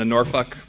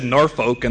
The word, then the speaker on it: Norfolk: My first flight, I flew into Norfolk, Virginia. That is how you pronounce it. I said Norfolk, and they corrected me. No, you know, from the audience, they yelled at me, no, it's Norfolk.